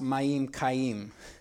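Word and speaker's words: mayim chayim: The best is fresh running water, such as flows from a spring or stream, which is called living water. It's pronounced Mayim Kayim in Hebrew.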